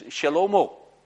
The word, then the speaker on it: Shlomo: And then Solomon's name, pronounces Shlomo. That's his Hebrew pronunciation, Shlomo.